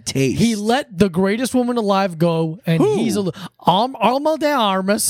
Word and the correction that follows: Ana de Armas: Oh, yeah, say her name right. – Ana de Armas.